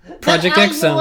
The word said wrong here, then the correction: Project X Zone: Excuse me, actually, you are wrong about one thing. It's pronounced Project Cross Zone.